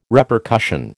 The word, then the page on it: Repercussion: American English